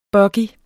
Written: buggy

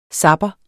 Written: zapper

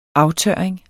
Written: aftørring

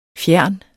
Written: fjerne

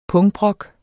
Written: pungbrok

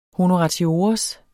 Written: honoratiores

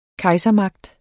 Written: kejsermagt

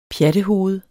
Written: pjattehoved

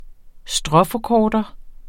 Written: stråforkorter

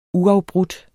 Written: uafbrudt

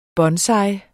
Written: bonsai

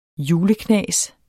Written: juleknas

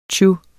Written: tju

